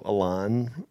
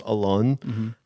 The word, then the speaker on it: Alon: I mean, I changed the pronunciation of my name to Alon versus Alon, because people would call me Alone, and it made me feel lonelier than I already felt. I